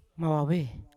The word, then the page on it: mavave: Yawa